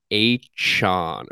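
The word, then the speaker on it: Achane: But Schefter tweets out today that his name is technically pronounced like A-chan or A-chan. Excuse A-chan. – Me. That's what it is. H-an. Which A-chan.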